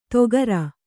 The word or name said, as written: ತೊಗರ್